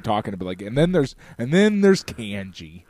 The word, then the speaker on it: kanji: So the correct pronunciation of the character system in Japanese is kanji, because... K-A is always rendered as ka, okay? – Yeah. – It's ka-ki-ku-ke-ko, okay? That's how, Japanese syllables work, okay?